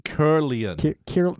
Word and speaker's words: Kirlian: So, yeah, that's K-I-R-L-I-A-N. – Yeah, Kirlian. – They pronounce it Kirlian.